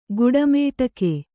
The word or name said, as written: ಗುಡಮೇಟಕೆ